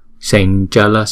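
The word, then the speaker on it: sañcala: Chữ này đứng một mình đọc là sam, nhưng mà vì theo sau là chữ chờ cho nên chúng ta phải chuyển thành chữ nhờ. Sanchala, sanchala, tara tara, santara, santara.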